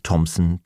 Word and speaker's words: Thompson: Außerdem muss man die Ausnahmen kennen. Als denglische Patienten brauchen wir uns nämlich gar nicht mit der Aussprache von TH abzumühen, wenn es ganz einfach wie ein T gesprochen wird, in Namen wie Theresa, Thomas, Thompson, Thames, Thai, Lesotho oder Thyme, dem Gewürz Thymian.